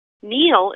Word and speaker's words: Neil: Neal is spelled N-E-I-L, and where I live, everybody wants to spell it N-E-A-L, but my husband's family was from Scotland originally, and they were originally McNeals, so now I am Jenny Neal.